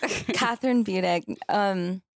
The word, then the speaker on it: Kathryn Budig: It's German, actually. Oh. Mm-hmm. So who knows? We could be pronouncing it wrong. – Yeah, but exactly. That's how my dad calls it. Yeah. – Kathryn Budig.